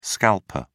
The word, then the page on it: scalper: British English